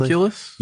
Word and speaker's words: homunculus: People were conducting experiments to see what would happen, to try to create life, with a homolecus, or am I pronouncing that correctly? – Homunculus? – Yes.